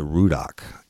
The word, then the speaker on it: Ruddock: There's only one exit in between Man Shack and La Place, which is probably a stretch of about 10 miles, and that's the Rudock exit. It's spelled R-U-D-D-O-C-K.